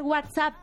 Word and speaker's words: WhatsApp: de esa herramienta, se tiene que pronunciar WhatsApp.